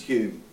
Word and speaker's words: Home: You might say Home, but it's pronounced Hume, and it's Scottish.